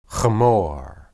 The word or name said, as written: hemor